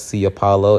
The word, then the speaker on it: Ciopaolo: The Space Between Us, Sia Paulo. It's spelled C-I-O-P-A-O-L-O.